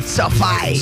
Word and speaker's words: SoFi: ¿Cómo lo que se pronuncia? ¿Sofai o Sofi? No cambia tanto. – Lo que pasa es que nosotros le decimos Sofi Stadium. – En castellano. – Claro. Pero Sofi.